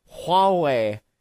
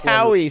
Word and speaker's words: Huawei: He's like, Huawei. Howie? I thought I was cracking up so hard. I was like, yeah. It's pronounced Howie, Steve. Say it with me.